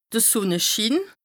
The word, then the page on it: de Sùnneschiin: Bas-Rhin; Strasbourg